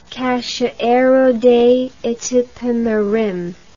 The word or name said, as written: Cachoeiro de Itapemirim